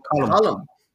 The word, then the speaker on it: Colm: Colin Livadet and Brave Inga. – Am I saying it right, though? Is it Colm or Colin? – No, Colm.